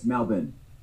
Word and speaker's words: Melbourne: It's Melbourne. Burn. Melbourne. Not Melbourne. Melbourne. – Melbourne. There you go.